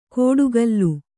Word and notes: ಕೋಡುಗಲ್ಲು: kōḍugallu

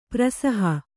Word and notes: ಪ್ರಸಹ: prasaha

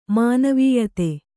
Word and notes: ಮಾನವೀಯತೆ: manāvīyate

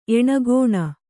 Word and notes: ಏಣಗೋಣ: ēṇagōṇa

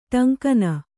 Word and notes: ಟಂಕನ: ṭaŋkana

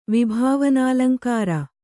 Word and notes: ವಿಭಾವನಾಲಂಕಾರ: vibhāvanālankāra

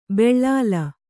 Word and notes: ಬೆಳ್ಳಾಲ: beḷḷāla

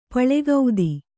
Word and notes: ಪುಲಕಿತ: pulakita

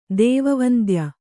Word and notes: ದೇವವಂದ್ಯ: kannada

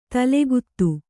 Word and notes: ತಲೆಗುತ್ತ: talegutta

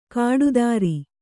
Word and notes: ಕಾಡುದಾರಿ: Kannada; kāḍu dāri

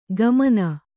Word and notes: ಗಮನ: kannada

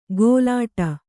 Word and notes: ಗೋಲಾಟ: gōlāṭa

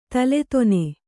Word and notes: ತಲೆದೊನೆ: taledone

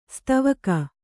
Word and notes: ಸೇದೆವುಗು: sēdevugu

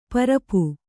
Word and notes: ಪರಪು: Kannada

